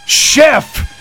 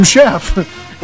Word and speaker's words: Szefc: But if you were spelling that, trust me, you would not say Sheff. His last name is spelled S is in Sierra, Z is in Zulu, E is in Echo, F is in Foxtrot, C is in Charlie. S-Z-E-F-C. It's clearly Sam Sheff.